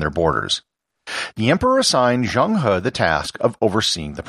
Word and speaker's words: Zheng He: And here I should note the pronunciation of Zheng He. If you read the title of this episode and you speak English, you might pronounce it as Zhang He. I'm trying to stay true to the Chinese pronunciation as much as I can.